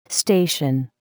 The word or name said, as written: station